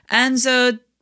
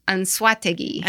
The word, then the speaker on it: Anzoátegui: So he's played in the Venezuelan, the Winter League since 2014 with the Caribes de Anzoátegui. – Anzoátegui. – Yeah, I need to help with that too. – Anzoátegui.